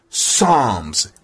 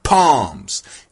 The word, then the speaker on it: Psalms: Everybody knows that the P on the front of Psalms is silent. It's Psalms. It's not palms.